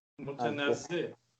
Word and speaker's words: متنزه: منتزه دید. اگر درست تلفظ بکنم که یعنی چشم نواز علف و آب بسیار متنزه. – چی گفتم؟ متنزه عذر میخوام.